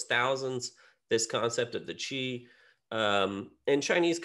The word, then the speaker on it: Qi: And Qi, which is usually spelled Q-I, but they spell it C-H-I, so we can kind of correctly pronounce, translates literally as air.